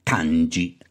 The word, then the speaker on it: kanji: Ecco, non dobbiamo introdurre una parola che è sovente citata in questo libro, la parola, spero di pronunciarla bene, ganji, sì. – Kanji. – Ah, kanji. Kanji.